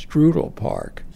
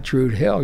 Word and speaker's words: Druid Hill Park: First of all, you mispronounced it. It's Drudel Park. You, you've heard that? That's the way Baltimoreans say. They say Drudel, not Drude Hill. You're showing yourself to be a foreigner.